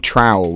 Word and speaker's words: Trowell: But I have been brought up in Trowell, which is just outside of Ilkeston. – Nice. – So Eastwood... – Trowell does not rhyme with Plowell, but we'll go with it.